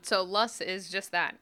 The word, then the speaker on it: loess: what you do see, though, is loess. – Right, and loess is spelled L-O-E-S-S, if you're about to Google that.